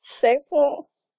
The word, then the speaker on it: Sabroe: Sabro. – Sabro? – Sabro. – Nej, uden E. Du skal ikke udtale E'et.